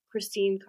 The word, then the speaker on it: Krystine: And my Instagram profile is @ Christine Cardona, PA. And my name is spelled K-R-Y-S-T-I-N-E. My last name is C-A-R-D-O-N-A.